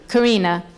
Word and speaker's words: Karina: How do you pronounce her name? – Karina.